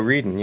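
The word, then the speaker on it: Wreden: Is that how you say it? Reedon? – I believe so. Reedon, yeah.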